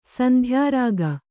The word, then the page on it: ಸಂಧ್ಯಾರಾಗ: Kannada; sandhyārāga